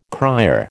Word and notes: cryer: American English

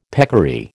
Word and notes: peccary: American English

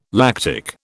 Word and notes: lactic: British English

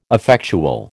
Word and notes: effectual: American English

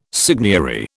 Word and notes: seigniory: British English

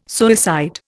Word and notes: suicide: Indian

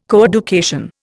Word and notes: coeducation: Indian

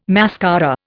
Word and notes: mascara: Indian

